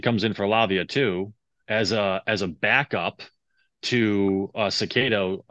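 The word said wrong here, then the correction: Caicedo: I just butchered that name. – Casado. – Big time. Casado.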